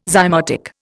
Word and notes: zymotic: Indian

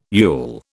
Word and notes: yule: British English

